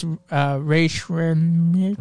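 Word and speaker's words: Rae Sremmurd: Okay, so I don't like, I never said the name right. It's Ray Shremmerd.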